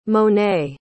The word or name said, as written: monet